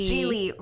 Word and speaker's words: Geely: Geely rhymes with really.